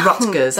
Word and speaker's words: Rutgers: Rutgers. Have I pronounced that correctly? – Yes.